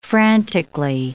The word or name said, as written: frantically